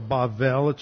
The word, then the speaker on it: Babel: I'm pronouncing it with the Hebrew pronunciation, Babel, with the emphasis on the second syllable. Babel or Bavel, this is a hard B, this is a soft B, so it's sometimes pronounced like a V, Bavel.